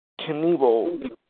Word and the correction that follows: Knebel: Uh, it's spelled K N E B E L.